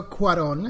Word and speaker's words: Cuarón: Yeah, it is Alfonso Cuarón. And if I want to make it a little bit more anglicized, I guess it would be Cuarón, which is quite hard to say, actually.